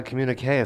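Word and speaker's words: communiqué: A communique is so glamorous. – Did I say it right? – You did.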